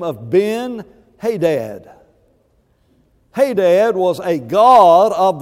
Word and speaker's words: Ben-Hadad: Ben-Hadad is the way we pronounce it.